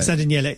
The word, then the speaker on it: San Daniele: San Daniele.